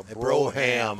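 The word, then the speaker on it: Brougham: I tell you, my first car was an 86 Cutlass Supreme Brome, white with red plush velvet interior. – But you really need to learn how to pronounce it. It's called a Broham.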